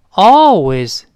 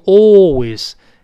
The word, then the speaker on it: always: Always 叫总是怎么怎么样，这个不要读 always，在美音中呢，口型大一些，读成哦哦，就是我们好像恍然大悟的声音哦，明白了那个哦，跟老师读一遍，always。